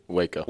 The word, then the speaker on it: Waco: What is it? Waco? Did I say it correctly? – Waco.